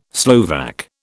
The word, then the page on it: Slovak: British English